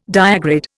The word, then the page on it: diagrid: Indian